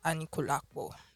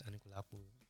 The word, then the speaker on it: Anikulapo: Did I pronounce it well? Sorry to every Yoruba person. I'm not Yoruba. No, it's correct. It's... Yeah, no, the pronunciations are as simple as that. Anikulapo, just tiny. Those are just little, but it's correct.